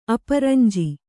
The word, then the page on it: ಅಪರಂಜಿ: Kannada